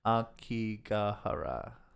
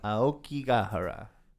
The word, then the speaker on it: Aokigahara: Aokigahara. – There you go. That one. – Aokigahara.